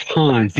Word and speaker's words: Hans: I'm pretty sure it's Hans. You got to get that long A in there.